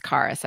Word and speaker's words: Charis: My name Charis means grace. – Oh, that's awesome. – And um although it's, it's Greek word and it's supposed to be pronounced charis, I believe.